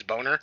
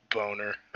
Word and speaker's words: Boughner: And then she found a headshot, and his name was Ralph Boner, B-O-U-G-H-N-E-R, or some shit like that. So, like, phonetically, it's Boner. – Yeah. – And she's like, your name's Ralph Boner? And then he's like, Boner.